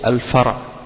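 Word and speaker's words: al-fara (فرع): Par opposition c'est al-fara. Le pluriel de fara c'est furu'. Al-fara ça s'écrit fa-ra-ayn. Fa-ra-ayn.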